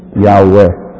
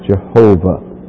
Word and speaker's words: Yahweh: The word Lord, you'll see that it's all capital letters in your Bible, is that Hebrew word Yehovah, and we pronounce it Yahweh in English. That's the way it should be pronounced. Generally, it is pronounced as Jehovah, and that's all right too.